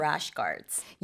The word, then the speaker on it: rash guard: rash guards. – Yeah, that's mm-hmm. right. Mm-hmm. 이 rash guard가요, 지금 발음 여러분 들으셨나요? 래시가드 L 아니고요. – Mm-hmm. – Rash guard R예요.